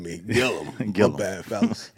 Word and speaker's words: Gillum: What's my man's, what's the proper pronunciation of Andrew? – Is it Gillum? – Gillum. Okay, my cousin was saying, I think I was saying Gilliam.